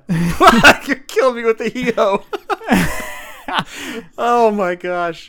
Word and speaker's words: Hijo: Jeff, the H is silent. – Oh. – Hijo.